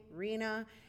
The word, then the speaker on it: Rhina: And also you can follow me at Colors of Rena, and or you can follow me on Rena Valentine, but my name is spelled R-H-I-N-A, kind of like the Rhine River, except it's with an A, Rena.